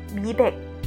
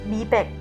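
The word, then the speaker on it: 미백: 第一个美白， 미 백 미 백， 미 백，这个 백，再次强调一下，就跟我们数字的这个百是一样的哟，一样的哟，百白，所以它的韩文发音是一样的哟，美白， 미 백， 미 백， 미 백。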